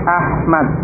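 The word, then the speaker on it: Ahmad (أحمد): Ahmadu, Ahmad, bukan Ahmad, tapi Ahmad, ya, dengan ha.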